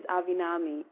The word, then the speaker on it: Avinami: It's S-I-L-V-A-N-A. And my surname is Avinami, A-V-I-N-A-M-I. For Spanish speakers out there, it's quite easy to pronounce my name,